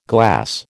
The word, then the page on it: glass: American English